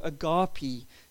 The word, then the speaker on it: agape: In Greek, there's a number of different words for love, but the word used here in this passage is the word agape, or agape, depending on which pronunciation you use.